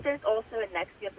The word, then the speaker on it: NXIVM: How do you say that? – Nexium. – Oh, it is Nexium.